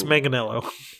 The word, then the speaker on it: Manganiello: Mangello? Is that how you say it? – No, it's Manganiello.